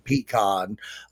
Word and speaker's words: pecan: I'm just going to spout off my favorite thing outside of what I know what you're going to bring up, which rhymes with recon, pecan.